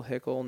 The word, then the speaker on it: Hickl: My last name is spelled H-I-C-K-L. So Will Hickle, no E.